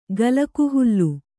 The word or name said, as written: ಗಲಕುಹುಲ್ಲು